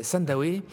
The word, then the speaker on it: Sandawe: comment est-ce que vous prononcez Zimbabwe je prononce Zimbabwe. parce que ça s'écrit de la même façon. Alors, Sandawe, c'est le nom d'une tribu africaine euh, dont l'histoire nous a beaucoup plu.